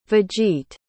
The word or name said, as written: vegete